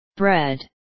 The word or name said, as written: bread